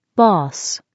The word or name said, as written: boss